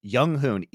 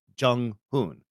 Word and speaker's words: Junghoon: They still call Junghoon Younghoon, even though his fucking real name in Korean is Junghoon. That's his first name. That's how you say it. It's not controversial. There aren't other ways to pronounce it.